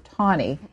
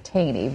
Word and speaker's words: Taney: I'm going to ask you to also briefly, because we'll spend more time later, who was Roger Taney? Now, people are going to look at that name and always want to pronounce it Taney, but the common pronunciation is Taney, correct? – I think Taney is correct.